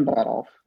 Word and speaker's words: Budolf: Yeah, so Stephen Budolf. Yeah, it's like Rudolf, but with a butt in front of it.